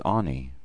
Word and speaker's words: Ani: The first breath is Ani. Ani. This Hebrew word, spelled Aleph Nun Yod, means I am.